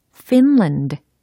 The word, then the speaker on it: Finland: 어, 우리말로 하면은 약간 핀란드 이것처럼 리을 받침이 되잖아요. 혹은 핀란드 이렇게 잘못 발음하게 되는 경향이 있는데 영어로는 fin 그다음 land finland finland 이렇게 조합을 하시면 되는 겁니다.